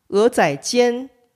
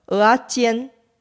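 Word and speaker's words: 蚵仔煎: 通常台湾人会把鹅仔煎念成台语的鹅啊煎。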